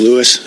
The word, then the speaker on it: Lewis: Yeah, my name's Mike Lewis. It's spelled L-E-W-I-S.